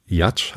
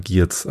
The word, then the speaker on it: Giertz: Ja, und aber mein, mein Lieblingsvortrag beziehungsweise mein, mein Lieblingsgast äh, war Simone Jatsch, habe ich jetzt gelernt, spricht man sie aus. Ich dachte immer Giertz, aber sie wird wohl Jatsch ausgesprochen.